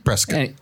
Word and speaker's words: Prescott: How do you say it? – Prescott. – I've lived here my whole life and I don't know. – Prescott. – Okay. Prescott.